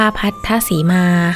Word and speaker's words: พัทธสีมา: พัทธสีมา